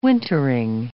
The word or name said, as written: wintering